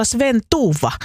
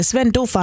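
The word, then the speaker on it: Sven Dufva: Missä teoksessa on hahmo Sven Duva, että se olisi väärin, että pitäisi olla Sven Tuva, niin ei tarvi olla. Kyllä se Sven Duva on se alkuperäinen hahmo ja sitten kun suomalaisille tuommoinen Duva on liian vaikea ääntää, niin se sitten vielä suomennettiin Sven Tuuvaksi.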